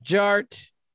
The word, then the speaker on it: Jart: And my name is Jared, aka DJ Art, with two T's for a double dose of that tink tink. The D is silent, so it's just Jart.